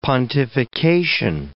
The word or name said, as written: pontification